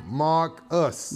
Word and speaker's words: Markus: Mark Us. – Mark Us. That's spell right. It. Spell it. – Marcus. – Spell it. – M-A-R-K-U-S. Mark and Us.